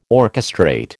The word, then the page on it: orchestrate: American English